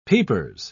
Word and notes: peepers: British English

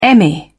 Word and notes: emmy: British English

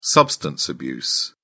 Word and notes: substance abuse: British English

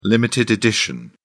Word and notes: limited edition: British English

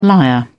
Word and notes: liar: British English

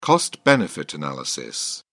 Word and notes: cost benefit analysis: British English

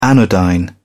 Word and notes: anodyne: British English

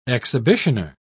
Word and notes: exhibitioner: British English

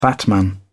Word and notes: batman: British English

